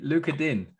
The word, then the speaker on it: Lucas Digne: Lucas Digne. Digne. Digne. – You mean Luca Din? – Luca Din. I'm not French. I'm not going to pronounce it that way.